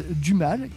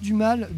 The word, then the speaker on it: Dumal: Alors, euh, sur la prononciation, moi j'aimerais bien prononcer à la française Dumal, car le nom du groupe vient des fleurs du mal de Charles ah bah, Baudelaire. en bah cas, c'est prononcé français. Hein. Je pense que c'est prononciation française. – Dans, ce cas, c'est prononcé à la française.